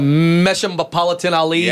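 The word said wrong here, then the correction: Mahershala Ali: Yep. – Oh, Mershala Ali.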